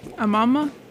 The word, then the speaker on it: Amama: Amama? A-M-A-M-A is how it's spelled, and the song was Alone in Brussels.